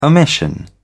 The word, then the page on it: omission: British English